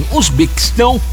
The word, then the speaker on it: Uzbequistão: A partida é marcada aí para as 14 horas e às 23 horas, Uzbequistão, falei certo também, Uzbequistão pega a Colônia.